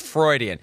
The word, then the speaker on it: Freudian: After 2.5 years of doing this show, you finally nailed it with Freudian. – Thank you. – I have heard every possible pronunciation. – Yes. – None of which were even remotely accurate. Freudian, extra syllables.